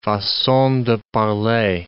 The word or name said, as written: facon de parler